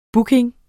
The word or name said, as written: booking